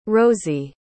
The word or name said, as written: rosie